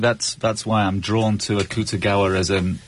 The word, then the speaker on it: Akutagawa: Akuga Tawa. I hope to have pronounced it well. Akutagawa, ok.